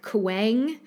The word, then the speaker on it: Kueng: Next, we have a character that I believe you would pronounce this like Kueng. It's spelled K-U-E-N-G.